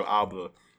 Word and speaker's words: Abloh: I know it's been about a week for that boy Virgil Abloh.